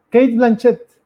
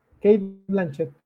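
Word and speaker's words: Cate Blanchett: Kate Blanchet. Kate Blanchet, muy bien.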